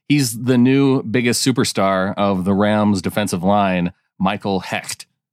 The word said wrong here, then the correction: Hoecht: Yeah, and I found out it's pronounced Hoyt. Hoyt. Which I don't know. H O E C H T makes a Y sound, but go on. However, from what I understand, it's Hoyt.